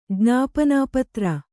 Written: ಜ್ಞಾಪನಾಪತ್ರ

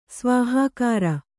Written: ಸ್ವಾಹಾಕಾರ

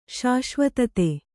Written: ಶಾಶ್ವತತೆ

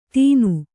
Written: ತೀನು

